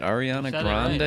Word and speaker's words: Ariana Grande: 3-3-1. Let's go. I'm on. Let's go. – Ariana Grande. How do you pronounce that? Ariana Grande? – Right, yeah, okay.